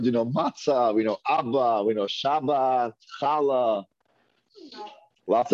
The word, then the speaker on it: Abba: What does Abba mean? Father. Father, Abba. Aleph, Bet Aleph. The second Aleph is silent. Abba.